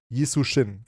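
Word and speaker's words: Yi Sun-sin: von Isujin, dem jetzt habe ich den Namen vergessen. Der Admiral, wie heißt der? Das muss ich jetzt nachgucken. Isushin, glaube ich. Isushin, genau. Okay, habe ich richtig gesagt.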